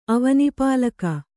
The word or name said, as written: ಅವನಿಪಾಲಕ